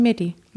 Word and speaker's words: Meddi: Aber man schreibt ihn auch mit Doppel-D. – Ja, eben, und ihn schreibt man Martha, Emil, Dora, Dora, Ida. Genau. Dann würde ich sagen Medi.